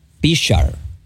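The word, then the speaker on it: Prichard: Padre, también nos unimos a, ¿verdad?, a, orando para que Dios le dé paz y fortaleza a la familia del exboxeador Pichar Colón. Déjame pronunciar bien, porque algunos dicen Richard y es Pichar, con P.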